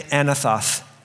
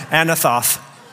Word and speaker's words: Anathoth: Everyone say Anathoth. And it sounds at the end like you're lisping, Anathoth, but that's correctly.